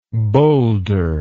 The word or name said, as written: bowlder